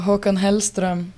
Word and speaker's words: Håkan Hellström: Oh, Håkan Hellström.